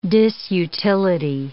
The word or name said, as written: disutility